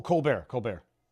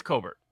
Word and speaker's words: Colbert: Colbert. – Yeah, that's right. It is Colbert.